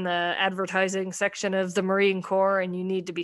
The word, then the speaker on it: Corps: Corps. But at first he was pronouncing it like Marine Corpse.